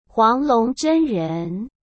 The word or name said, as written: huanglongzhenren